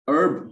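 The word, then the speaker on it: herb: अर्बीवोरस कहते हैं जो कि हर्ब्स खाते हैं, जिसको हम हर्ब्स कहते हैं ना, हर्ब्स एच से लिखते हैं, लेकिन हर्ब्स को प्रोनाउंस नहीं करते, अर्ब प्रोनाउंस करते हैं, ठीक है।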